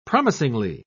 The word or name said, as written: promisingly